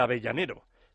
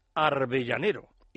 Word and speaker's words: Avellanero: En una de aquellas películas cortas, Miguel de Molina cantaba El Avellanero, de León y Quiroga, perteneciente al cortometraje Pregones de Embrujo. Es del año 1941. Miguel de Molina se empeñó en pronunciar Arbellanero.